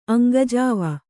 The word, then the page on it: ಅಂಗಜೀವಿ: aŋgajīvi